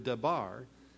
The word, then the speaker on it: dabar: It's translated from the word dabar. D-A-B-A-R is the way it's spelled, dabar.